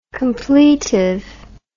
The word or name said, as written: completively